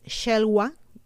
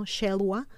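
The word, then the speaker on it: Xelhua: Cuando las aguas bajaron, uno de los gigantes Shelwa, me, parece que así se pronuncia con X, ¿no? Shelwa, llamado el arquitecto,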